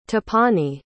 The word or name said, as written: tapani